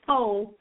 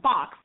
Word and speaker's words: faux: L-A-U-X. – U-X. – She said some fox. No, it's faux, not fox.